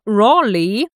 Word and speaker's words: Raleigh: Kleiner Fun Fact, der Sir Walter Raleigh,